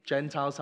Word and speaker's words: Gentile: Looks a bit like Gentile. Gentile's how it's said.